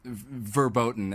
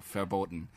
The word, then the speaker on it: verboten: And if you are sort of in love with someone and it's a, verboten or whatever, verboten, as Kifo corrected me. Well, if it's actual German, the V is pronounced like an F, so verboten.